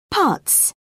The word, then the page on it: putz: British English